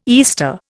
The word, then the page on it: Easter: Indian